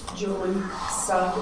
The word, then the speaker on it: Julie Sugg: Julie Sugg.